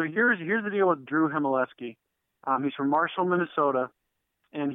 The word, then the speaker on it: Heimeleski: Yeah, so I got a text sign about 6:30 from Drew Heimeleski. That's how you pronounce his name for all the fans looking at his name. It's him, I-L-ski. So Drew Heimeleski.